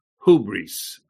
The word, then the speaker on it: hubris: Our subject was hubris. H-U-B-R-I-S, but there is no H in the Greek. It's a little apostrophe. It means that you pronounce it with an H, hubris, okay?